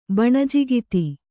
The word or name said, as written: ಬಣಜಿಗಿತ್ತಿ